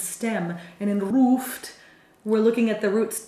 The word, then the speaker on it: Ruft: We have L-U-F-T and R-U-F-T. – Yeah, but they are pronounced differently. In Luft, we have a short, opened U sound, O, Luft. And in Ruft, this comes from Rufen, from the word Rufen, where it's a long and closed U sound, Rufen.